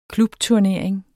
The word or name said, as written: klubturnering